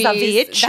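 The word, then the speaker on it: The VVitch: The Vitch. I don't know how to say it. Like, two V's. – The Vitch.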